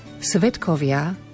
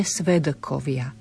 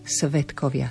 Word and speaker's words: svedkovia: Svedkovia vyslovujeme s T v strede slova, nie svedkovia, svedkovia, spodobujeme.